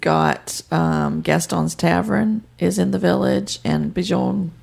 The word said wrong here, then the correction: Bonjour: I said that wrong. – Bonjour.